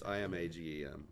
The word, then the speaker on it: Imagem: It's Amalgam or Imagem or. – Yeah, say Amalgam. Yeah. – So it's Imagem.